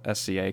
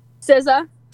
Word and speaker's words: SZA: So I saw on your story earlier this morning, actually, that you are planning on dropping an acoustic SCA cover. How do you say the name again? – SZA.